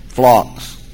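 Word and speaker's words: Phlox (φλόξ): Phlox. That's the way we pronounce it, phlox.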